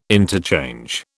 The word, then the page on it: interchange: British English